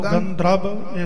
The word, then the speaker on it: गंधर्व: गंत्रव हां जी अद्धे रारे की आवाज आवेगी गंधर्व दे नाल पूरा रारा जिहड़ा है वह बोलिया जाता है सो गंधर्व इस तरह इनका शुद्ध उच्चारण है जी